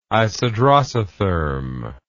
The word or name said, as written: isodrosotherm